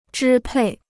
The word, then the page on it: 支配: zhī pèi